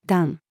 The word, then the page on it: 壇: female